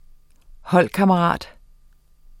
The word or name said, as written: HOLDKAMMERATEN